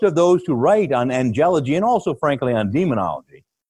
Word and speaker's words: angelology: Some people like to pronounce it angelology, but you know that's not really how we we we, we always put the accent on the penult, right? of the syllable. So properly in English, the study of angels is called angelology. But that logy or that ology is exaggerated a little.